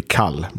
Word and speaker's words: Kall: Yeah, like your most active band would be Kall. That's spelled Call, but with K.